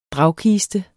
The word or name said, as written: dragkiste